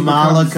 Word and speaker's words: Kamala: And then it's going to be Pelosi Kamala. How do you say her name? – Kamala.